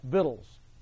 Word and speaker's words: victuals: So they carried burdens, they bought and sold V-I-C-T-U-A-L-S. Look it up, vittles. That's how it's pronounced. I know, can't pronounce that word as victuals. No, it's not. It's vittles. Look it up.